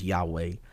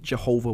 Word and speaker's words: Yahweh: So the best that we can pronounce it that we know of is Yahweh. It used to be Jehovah.